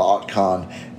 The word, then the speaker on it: Otkon: So it's spelled O-T-K-O-N. So okay. Forgive me if I'm not pronouncing it right, but Otkon. – Yeah, sounds right to me.